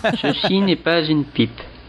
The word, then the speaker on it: Ceci n'est pas une pipe: Ceci n'est pas une pipe.